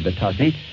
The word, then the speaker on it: Batasi: I think you pronounce it B-A-T-A-S-I, Batassi.